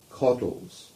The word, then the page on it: Coddles: English